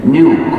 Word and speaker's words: GNU: Y es la palabra la más cargada de humor del idioma inglés porque según el diccionario la G es muda y la palabra se pronuncia new, como nuevo.